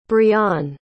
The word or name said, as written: brionne